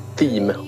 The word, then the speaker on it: Thiem: så måste jag ju flika in att eh, jag sitter och följer Wawrinka mot Dominic Team om vi mm. ska gå enligt engelska kommentator, kommentatorers pronunciation. Precis.